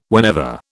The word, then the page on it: whenever: British English